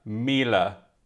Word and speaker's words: Miele: Somebody DM'd me and said, hi, I think I really need to tell you this, that Miele is pronounced like Sheila, Miele.